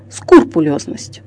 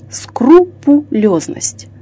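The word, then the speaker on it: скрупулёзность: Слово «скрупулезность» созвучно с русским словом «крупа» – «скрупой», то есть «по крупинке», а значит произносится как «скрупулезность», «скрупулезный».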